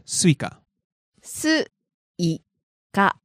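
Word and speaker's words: Suica: Now, is there a pitch accent difference between the two? – I think so, a little bit different. Yeah. – Can you give us the two pronunciations? First, give us watermelon. – Suika, suika. – And now, the train pass, suika.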